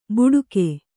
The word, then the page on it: ಬುಡುಕೆ: buḍuke